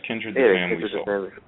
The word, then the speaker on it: Kindred the Family: So everyone, make sure that you check out the site on May 1st for the big relaunch with the live interview with Kindred the Family. Did I say it right? Yes, Kindred the Family.